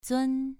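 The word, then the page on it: zun: tone 2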